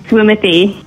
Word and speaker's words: Swimothy: So it's SWIMATHEE, Swimothy. – Love it. I mean, Swimothy, as in like Timothy Chalamet.